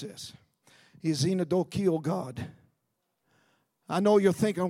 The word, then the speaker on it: xenodochial: That sounds like it starts with a Z instead of an X. Yes, it does sound like it starts with a Z. But it starts with an X. And it's spelled X-E-N-O-D-O-C-H-I-A-L, which means friendly to strangers.